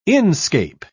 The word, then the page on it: inscape: British English